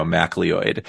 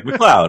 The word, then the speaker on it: McLeod: So Comic Con, I once asked Bob McLeod how to pronounce his name because, like, since I was a kid, I was calling him, you know, MacLeod. – Oh, right. – And he's like, McLeod.